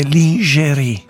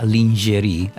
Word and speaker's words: lingerie: Si dice lingerie. – Ah, lingerie, lin, perché francese si pronuncia così, lin.